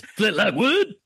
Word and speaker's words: Flint Lockwood: That's not how you pronounce his name. It's Flint Lockwood.